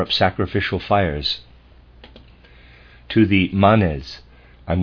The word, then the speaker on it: Manes: I believe M-A-N-E-S is the plural. I'm going to pronounce that Manes.